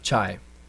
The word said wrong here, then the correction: chia: Well, I don't know what they're other pronounced, but chia. – Chia seeds. – It looks like chai. – That sounds like what I'm thinking of. – It's chai with the I and the A switched around.